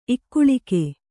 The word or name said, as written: ಇಕ್ಕುಳಿಕೆ